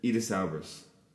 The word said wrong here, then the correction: Idris Elba: Idris Elba? – Albra.